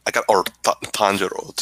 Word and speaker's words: Tanjiro: Tanjiro, just is pronounced correctly.